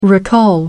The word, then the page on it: recall: American English